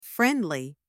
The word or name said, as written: friendly